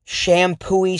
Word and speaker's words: Champoeg: There was one in Shampooy State Park, which is spelled Champoeg.